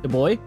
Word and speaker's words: DuBois: How do you say his last name? – Yeah. – DuBois? Yeah. Kevin DuBois.